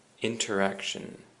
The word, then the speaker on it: interaction: Interaction. And the emphasis is on the ack. Interaction. And interaction means 互相影响 or 互动.